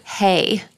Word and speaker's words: Haigh: So today we're going to go across the pond to the UK to meet a man named John George Hague. Or sorry, Hay. – Hey. – Hey. But it's spelled H-A-I-G-H, so it makes me want to say Hague. But it's Hay.